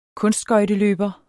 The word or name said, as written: kunstskøjteløber